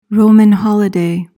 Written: Roman holiday

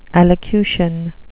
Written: allocution

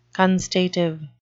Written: constative

